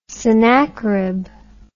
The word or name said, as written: Sennackerib